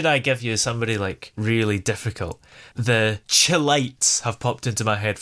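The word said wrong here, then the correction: Chi-Lites: You've got the name wrong for a start. It's the Shylights.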